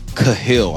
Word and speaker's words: Cahill: I got a Riley Cahill, I believe that's how you say his name, C-A-H-I-L-L, running back from Seattle Prep, 241 yards and three touchdowns.